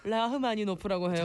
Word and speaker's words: 라흐마니노프: 아, 락마니노프. 이름 뭐예요? – 아, 뭐 어떻게 한다고요? – 락 라흐마니노프라고 해요.